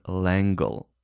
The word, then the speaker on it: L'Engle: So that's how my elementary school librarian pronounced her last name. Madeleine L'Engle. L'Engle.